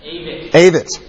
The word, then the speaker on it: Avett: Is that right? Avett? Is it Avett? – Avett. – The Avett Brothers.